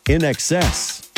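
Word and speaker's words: INXS: In excess.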